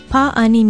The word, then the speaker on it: pa'ani: It is spelled P-A-Okina-A-N-I with a makron or kahako over the first A. Say pa'ani.